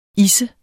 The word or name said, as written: isse